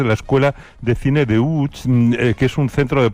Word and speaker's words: Łódź: Aunque se pronuncia Łódź, se escribe Lodz, por si alguien lo busca.